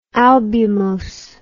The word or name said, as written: albumose